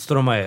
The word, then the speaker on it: Stromae: Jag skäms till och med att uttala hans namn. Alltså Stromae eller någonting. – Okej. Okay. Det är rätt uttalat. Stromae. Ja.